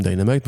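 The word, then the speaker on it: Die!Namite: Dynamite, donc ça se prononce comme Dynamite. En fait, c'est Die, D-I-E, comme le verbe mourir en anglais, point d'exclamation, Namite exactement comme DC, de tu oh, vois.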